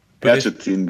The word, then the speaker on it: Ercetin: Dennis, wie wird dein Nachname ausgesprochen? Certin, bitte, danke.